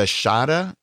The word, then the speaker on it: Shada: But you said they are re-releasing the Shada.